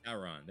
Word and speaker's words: Sauron: Sauron, there we go.